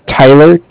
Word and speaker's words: Tyler: My name is Tyler, T-Y-L-E-R, and I'm calling with Cardiff. It's spelled C-A-R-D-I-F-F.